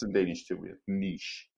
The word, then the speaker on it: niche: Please just try and say it French, not just niche.